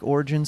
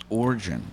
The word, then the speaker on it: origin: I love that you're saying origin like that. Origin. I've never told a fake origin. – Well, it's like oil. It's only one syllable, but everyone... – Origin. – Origin. O-R-I. Origin story. – What's the origin of? – Yeah, not O-R-G-N.